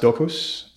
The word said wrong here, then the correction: Dobrus: Dobrus, sorry. – Yes, it's Do like Jindo and Bruce like Brusula.